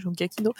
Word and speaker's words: Giacchino: Et le, le 3 et 4, c'est Giacchino. Je sais jamais si je le prononce bien ou pas. C'est Gaki, ouais, Giacchino Bon, je, valide.